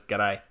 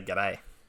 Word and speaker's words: g'day: So I'm going to say that as g'day. Number one. That's the proper way to say good day. You say g'day.